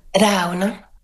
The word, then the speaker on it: Ravna: Is it Ravna? – Ravna. And it comes from the word for stream, which is Raunje in Sami language.